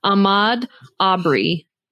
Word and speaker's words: Ahmaud Arbery: I think it's Ahmaud Arbery.